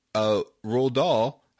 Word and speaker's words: Roald Dahl: and he pointed out that Roald Dahl, I got his name right, also did Fantastic Mr. Fox.